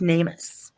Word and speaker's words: NamUs: So this is from it's pronounced Namus. And it's okay. NAMUS, and it's where, you know, I talked about a little bit earlier.